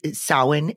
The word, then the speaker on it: Samhain: And that was with Samhain. Did I pronounce that correctly? – Yes.